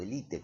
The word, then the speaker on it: élite: O élite, como se dice, porque se dice así en castellano, élite, está admitida, busqué en el diccionario, vaya, digo, tiene que apuntar a eso, y esto está mal visto, digamos, ¿no?